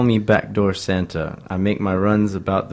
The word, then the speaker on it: Backdoor Santa: Vámonos ahora con Clarence Carter, que sacó un temazo en 1968, que se llama Backdoor Santa.